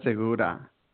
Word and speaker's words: Segura: And you're pronouncing it correctly. It is Segura, so your pronunciation is great.